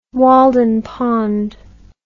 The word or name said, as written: walden pond